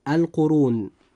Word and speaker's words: القرون: كلمة القرون جاء حرف الراء هنا مضموما، فيجب أن يفخم وينطق هكذا: القرون.